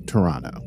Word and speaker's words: Toronto: So they just need to take that back to Toronto, which, by the way, I've been pronouncing like an American this whole time. Apparently, you're supposed to call it Toronto. I know that, and I'm sorry that I am mispronouncing the city name.